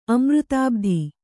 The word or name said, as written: ಅಮೃತಾಬ್ಧಿ